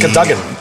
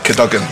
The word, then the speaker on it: Cadogan: We're also recording this episode in the recently refurbished Cadogan Arms, and we'll be catching up with MD... – Isn't it Cadogan? – Cadogan, yeah.